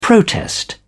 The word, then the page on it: protest: British English